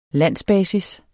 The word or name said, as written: landsbasis